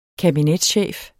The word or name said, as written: kabinetschef